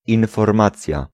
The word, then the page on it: renseignement: France; [ʁɑ̃.sɛɲ.mɑ̃]